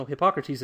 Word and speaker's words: Hippocrates: No, Hippocrates is correct. – I know. – I'm mispronouncing it a la Bill and Ted.